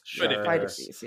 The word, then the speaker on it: Pheidippides: Still not 100% how to pronounce it. Pheidippides? – Pheidippides. – Pheidippides. Pheidippides. – Pheidippides, yes.